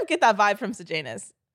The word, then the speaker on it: Sejanus: Sid Janus. Himself. – Good job. – I did it. I said it right, guys. Okay. – He sees Sid Janus.